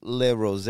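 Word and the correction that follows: Le Rosey: Like Institut Le Rosé.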